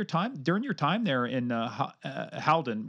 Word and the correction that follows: Halton: Did I say that right? Halden, Halton, yeah. Halton, I'm sorry, Halton.